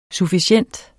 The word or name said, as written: sufficient